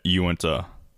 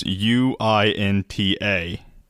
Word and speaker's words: Uinta: Well, it's in the middle of the Uinta Basin, and uh, I'm almost positive that's how you say it, Uinta. It's U I N T A. I think it's Uinta. – Uinta or Uinta, but you know Utah, but throw an N in there.